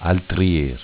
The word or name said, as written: Altrier